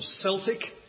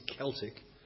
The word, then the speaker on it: Celtic: And Glasgow Celtic, spelt with a C and usually pronounced with a soft C, although normally when you see the name it's pronounced with a hard C, Celtic.